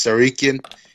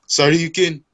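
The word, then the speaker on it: Tsarukyan: That's how you pronounce it. Silent T. It's Sarukian. It's like that one Dragon Ball Z game. Sarukian.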